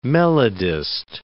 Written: melodist

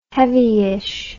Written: heavyish

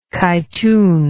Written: kytoon

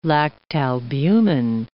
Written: lactalbumin

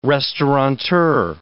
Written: restauranteur

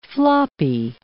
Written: floppy

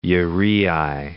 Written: uraei

